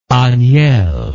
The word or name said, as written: agnel